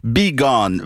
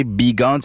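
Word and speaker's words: Beeghon'n: Le premier groupe que je vais vous présenter s'appelle Be Gone. Bon, c'est assez difficile là à chercher. Si jamais vous cherchez Be Gone, c'est B-E-E-G-H-O-N-apostrophe-N.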